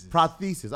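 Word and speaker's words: prosthesis: It is a man weave on the streets, but it's also known as a hair unit or a cranial parathesis. Did I say it right? – Prothesis. – That was close. – Yeah, a cranial prothesis.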